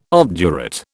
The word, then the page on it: obdurate: British English